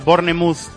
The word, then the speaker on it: Bournemouth: Y en la Premier ya ha terminado el Bournemouth 0, que se dice así, Bournemouth 0, Newcastle 1. – Bournemouth. – Sí, la E, Bournemouth. – Bournemouth. Ah, Bournemouth. Vale, pues Bournemouth.